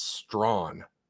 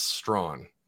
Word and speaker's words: Strachan: It's funny because it's spelled Strachan. And I'm like, I don't know how to pronounce that. And I saw it. It's Strawn.